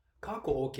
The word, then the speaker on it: Kāko’o: In terms of pronunciation, the A in Kāko’o has a Kāko’o over it, which means the emphasis is placed on that first vowel sound.